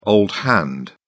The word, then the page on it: old hand: British English